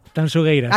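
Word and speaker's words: Tanxugueiras: Tanzugueiras, Tanzugueiras. – Sí, esto me lo corrige Fermín Agustín mucho. Marta, por favor, digo, mira que yo siempre pronuncio... A ver, otra vez, dímelo. – Tanzugueiras.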